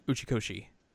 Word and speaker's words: Uchikoshi: Uchikoshi.